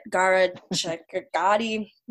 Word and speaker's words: Gharachedaghi: So Galnessa Gigi, I always butcher her last name and I feel so ignorant and white when I say it. Gharachedaghi, we all know Gigi from Shaws. Sure. I'm trying. – That sounds right.